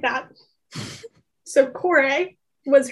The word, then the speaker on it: Kore: Okay, so Persephone's name was Core. K-O-R-E. At least that's how I pronounce it. – Core.